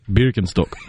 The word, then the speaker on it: Birkenstock: Like Birkenstock. Say that for me. – Birkenstock. – I had the short O. I'm not saying that correctly. Appreciate you correcting me on that as we practice our German here.